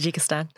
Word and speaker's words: Tajikistan: But before we do, Tajikistan. Okay, am I pronouncing that right? – Yes, that's correct. Yes, yeah. Tajikistan.